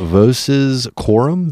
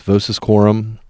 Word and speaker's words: Voces Quorum: Vo- Voces Quorum, V O C E S, Voces Quorum.